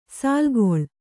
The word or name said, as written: ಸಾಲುಗೊಳ್ಳು